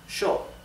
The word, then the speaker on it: yo: Therefore, the pronoun I, yo, is pronounced sho in Argentina and in Uruguay.